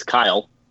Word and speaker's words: Kyle: It's Kyle, K-Y-L-E, Tsuchiya, T-S-U-C-H-I-Y-A.